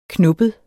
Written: knubbet

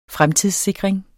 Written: fremtidssikring

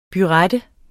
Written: burette

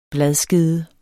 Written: bladskede

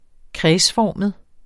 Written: kredsformet

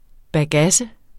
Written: bagasse

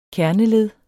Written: kerneled

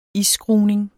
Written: isskruning

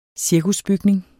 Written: cirkusbygning

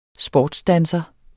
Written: sportsdanser

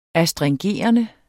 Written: astringerende

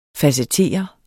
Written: facettere

